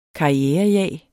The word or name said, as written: karrierejag